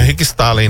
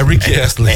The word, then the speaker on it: Rick Astley: Não é Rick Staley, não. É Rick Astley.